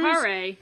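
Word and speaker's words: Kaare: It's K-A-A-R-E, so Care? Car? Andrews? – Carre. – Carre.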